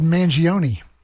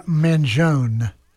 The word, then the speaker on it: Mangione: Now, I've got a guy, I think I've been saying his name wrong for years, but AI tells me something different. His name is Chuck Mangione. I always said Mangione. I don't know why, but his name is actually Mangione.